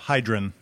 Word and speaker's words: Heidrun: So it's Heidrun, mythical Viking goat.